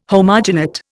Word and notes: homogenate: Indian

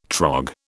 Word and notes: trog: British English

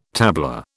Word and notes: tabla: British English

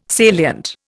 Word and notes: salient: Indian

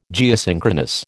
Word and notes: Geosynchronous: American English

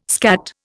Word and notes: skat: Indian

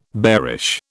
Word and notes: bearish: British English

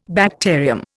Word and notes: bacterium: Indian

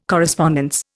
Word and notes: correspondence: Indian

